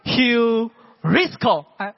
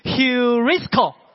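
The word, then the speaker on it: heurisko (εὑρίσκω): 那个字要怎么念，那个字叫做 heurisko，这样来跟我念一遍啊， heurisko，跟我来念一遍，来 heurisko，就是寻得的意思。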